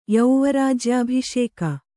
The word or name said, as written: ಯೌವರಾಜ್ಯಾಭಿಷೇಕ